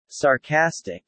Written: sarcastic

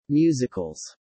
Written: musicals

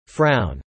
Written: frown

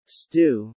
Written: stew

